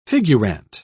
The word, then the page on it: figurante: British English